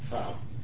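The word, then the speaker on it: Fau: Tim Fan? – Fow. – Fow, I apologize.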